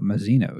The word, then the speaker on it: Mazino: In other casting news, Emmy-nominated actor Young Mazzano, Mazino, excuse me,